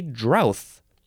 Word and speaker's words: drouth: It's D-R-O-U-G-H-T, drought, or you can spell it D-R-O-U-T-H, and then you would say drowth. I did not know that was also an okay way to say this word.